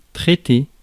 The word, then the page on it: traiter: [tʁe.te]; France